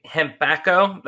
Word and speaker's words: Hempacco: So your company, Hempacco, that's how you pronounce it, right? – Yes.